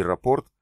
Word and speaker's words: рапорт: Им не нужно было объяснять, что компас и рапорт произносятся с ударением на последний слог.